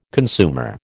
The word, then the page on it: consumer: American English